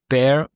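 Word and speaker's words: Bair: Theo, wird rausgesprochen. Theo, Bear, genau.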